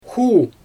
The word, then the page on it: xu: Xyzyl